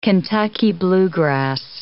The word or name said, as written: Kentucky bluegrass